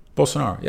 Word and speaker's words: Bolsonaro: Bolsonaro, yeah.